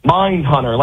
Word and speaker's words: Mind Hunter: The two-horse Mind Hunter, currently going off 12-1. That's the pick today. – Two-horse, what was it? Nine Hunter? How do you say it? – Mind Hunter, like the fantastic Netflix show.